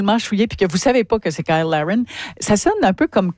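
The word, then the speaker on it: Cyle Larrain: Il y a un des joueurs de l'équipe canadienne qui s'appelle, ça s'écrit Cyl, C-Y-L-E, et Larrain. Mais bon, vous le prononcez à l'anglaise. – Kyle Larrain, oui, c'est ça.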